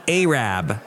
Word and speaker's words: Arab: Okay, it looks like it's spelled Arab, but they say Arab.